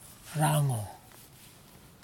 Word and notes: rangho: kwamera

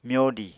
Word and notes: myodi: Apatani

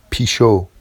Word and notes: piʃow: Vafsi

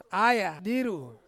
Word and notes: aya diru: Ansus

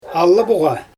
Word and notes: alaboGa: Xyzyl

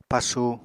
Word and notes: pasu: Apatani

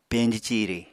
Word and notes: peñji ciri: Apatani

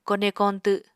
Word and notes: kone koñtw: Apatani

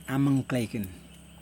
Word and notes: amuN klaikin: Yokoim

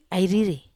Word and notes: ai riri: Wamesa

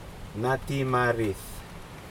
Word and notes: natimarid: Aneityum

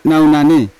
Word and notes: naunane: Yawa

